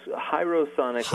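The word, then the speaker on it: Hierosonic: Is it Herosonic? – Close. Herosonic, like hieroglyphics.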